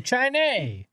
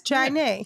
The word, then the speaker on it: Chiney: It's like the drink Chai and Nay. Chiney. – That's what I would guess. Chiney.